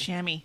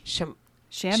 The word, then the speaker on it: chamois: Um, then two, a swimmer's how do you how do you say that word? Chamois. Sham- chamois. – Chamois. Sham, really? Okay, I know, it's- it's the it's I think well.